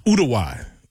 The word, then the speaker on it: Uruguay: Oh, and you pronounce it, and you actually pronounce it Uruguay. Yeah, that's how the natives pronounce it.